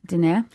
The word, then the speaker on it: Diné: She is, help me with the pronunciation. – Diné.